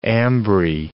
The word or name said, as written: ambry